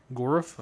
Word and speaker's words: Gorf: Like the Gauntlet of Gorf. Gorf? Am I pronouncing that right? – Well, yeah, it's almost Lovecraftian, you know? – Yeah.